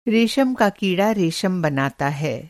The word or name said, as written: रेशम का कीड़ा